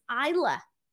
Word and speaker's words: Isla: Now, Michael Cole kept saying her name wrong. It's Isla. Isla Dawn.